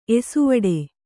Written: ಏಸುವಡೆ

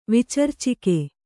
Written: ವಿಚರ್ಚಿಕೆ

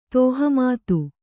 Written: ತೋಹಮತ್ತು

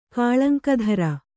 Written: ಕಾಳಂಕಧರ

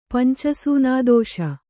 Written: ಪಂಚಸೂನಾದೋಷ

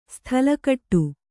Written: ಸ್ಥಲಕಟ್ಟು

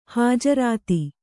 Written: ಹಜರತು

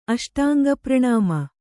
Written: ಅಷ್ಟಾಂಗಪ್ರಣಾಮ

